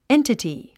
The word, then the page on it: entity: éntəti; エンタティ